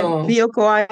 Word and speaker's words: Bioko: And actually there were quite a few Bioka. Is that how you pronounce it? – Yeah. Bioko Island.